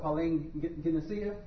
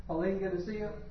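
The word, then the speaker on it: palingenesia: Phonetically pronounced Pauline Genesia. Pauline Genesia. And it's... you see the word Genesis in there? – Yeah.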